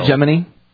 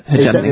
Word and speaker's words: hegemony: You know, the homogeny that we're looking to build. – Hegemony? I think it's hegemony.